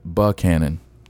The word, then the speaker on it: Buchanan: How do you say Buchanan? – It was brought to my attention just the other night that it Buchanan, some people may say Buckhannon if you're really from the South.